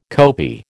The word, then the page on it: kopi: American English